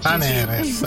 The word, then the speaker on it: Aneres: Invece è Serena al contrario, giusto? – Esatto, Aneres. – Aneres, quindi tu vai con l'accento sulla E.